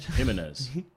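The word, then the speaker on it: Jiménez: Jimenez.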